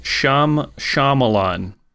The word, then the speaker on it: Shyamalan: Three syllables? – Yeah, I think so. M. Night Shyamalan? – Shyamalan. It's S-H-Y, though, right? – Well, now I'm on the spot.